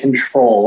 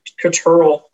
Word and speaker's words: CTRL: So is it pronounced, are you calling it CTRL or Control? – I've been calling it Control. What about you? – I call it Control.